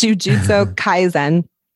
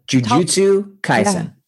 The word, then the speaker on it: Jujutsu Kaisen: I keep wanting to say Jujutsu so bad. It's Jujutsu Kaisen. – Yeah. So Jujutsu Kaisen.